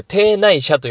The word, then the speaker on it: 邸内社: あれをまあ屋敷の中の神社と書いてですね、邸内社と読みますが、まあ内社なんかは特に数に入れられてない場合が多いですね。